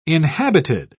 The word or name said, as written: inhabited